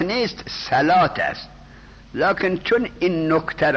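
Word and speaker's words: صلاة: صلوات نیست، صلات است. اون واوش تلفظ نمیشد، اقیم الصلات یا اقیم الصلوات، صلات. لکن چون واو را دیدن خیال کردن که این واو تلفظ میشد.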